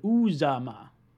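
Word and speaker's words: Uzomah: Uzma. See, everyone's telling me I'm pronouncing it wrong. It's Uzama.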